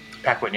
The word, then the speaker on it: Paquin: Paquin, yeah.